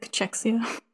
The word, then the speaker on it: cachexia: I don't even know how you say it, cachexia? – Yeah, it's like cachexia.